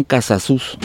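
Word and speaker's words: Casasús: Bien, el programa pasado también mencionamos que la familia Rivas Mercado tenían como vecinos, yo dije de una manera errónea, Casasus, y son Casasus, con acento en la U.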